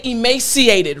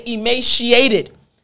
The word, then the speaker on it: emaciated: You heard me say emaciated, right? There are some individuals that say emaciated, some from Britain and some from America. Both are correct, so don't worry, don't be confused.